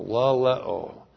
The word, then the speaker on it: laleo: The conjunction chi is the connective chi and then we have the verb laleo. L-A-L-E, long O, laleo.